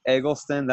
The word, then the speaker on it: Eggleston: His name is Tyler Eggleston.